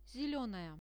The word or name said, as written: zelenaya